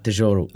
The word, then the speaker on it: Tesouro: Tesouro.